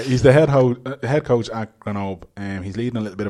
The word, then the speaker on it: Grenoble: I like to pretend I can speak French, so I say Grenoble. – you don't pronounce the L and the E.